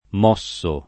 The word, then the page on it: mosso: m 0SS o